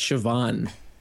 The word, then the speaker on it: Siobhan: That's Siobhan. – Siobhan? – Yeah. – Siobhan, okay. Sorry, I apologize, is a wealthy housewife whose fairy tale life is, in fact, hiding some darker secrets. And when Siobhan disappears,